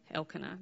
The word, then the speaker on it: Elkanah: It also says that Elkanah, is that how you say it, Marshall? Haley's nodding. Elkanah, that's what I'm calling.